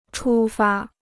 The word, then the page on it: 出发: chū fā